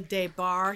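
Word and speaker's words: Des Barres: Pamela DeBar here. My ex-husband Michael always said it rhymes with gay bar, so that's how you can remember how it's pronounced. And it's spelled capital D-E-S, capital B-A-R-R-E-S.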